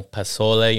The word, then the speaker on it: pozole: You know, I got to hit it with that young pozole, you know what I'm saying? Oh, and some tamales. Pozole? – Pozole? – Pozole. – Pozole. – Pozole.